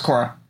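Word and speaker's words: Quorra: It's Q-U-O-R-R-A. I think it's pronounced Cora. I forget. – Yeah, Cora. It's pronounced Cora. Like the main character of the sequel to The Last Airbender, Legend of Cora.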